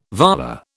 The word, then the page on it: volar: British English